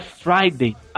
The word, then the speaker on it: Friday: Então, cara, a pronúncia é Friday.